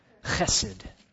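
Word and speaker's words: chesed (חסד): In the Old Testament, it was that covenant word, chesed. Got to get a little spit in there to say that properly. Chesed.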